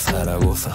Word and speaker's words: Zaragoza: Zaragoza, say it right, Zaragoza.